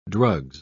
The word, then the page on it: drugs: British English